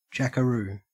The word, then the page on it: jackaroo: Australian English